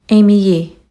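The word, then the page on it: Aimee Yee: English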